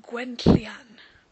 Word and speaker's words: Gwenllian: so it's got that nasty double L sound in it. Gotta love Welsh phonetics. So it's Gwenllian. But if you can't pronounce that, no worries. Gwenllian or just plain Gwen is fine.